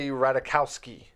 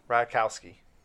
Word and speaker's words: Ratajkowski: Emily Ratajkowski. There's a J in there that's semi-silent that I can't pronounce.